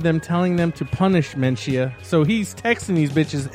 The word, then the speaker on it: Menchaca: Mr. Mencia? – It's Menchia. – It's spelled M-E-N-C-H-A-C-A.